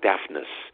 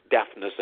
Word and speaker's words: Daphnis: We have the Maurice Ravel piece, Daphne and Chloe. Tell us a little bit about this. – It's pronounced. Excuse me. Since it's Greek, it's pronounced Daphnis. We do say the last N. It's Daphnis and Chloe.